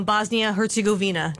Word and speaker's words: Bosnia Herzegovina: And they're taking on Bosnia Herzegovina. Did I say that correctly? – You did. – Thanks.